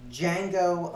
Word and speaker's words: Django: A few years later is Django Unchained. – The D is silent.